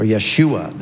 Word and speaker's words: Yeshua: That's why the angels, we're told the angels spoke very specifically to Joseph that the child's name was to be Jesus, or Yeshua, the Hebrew pronunciation, because he is going to save his people from their sin.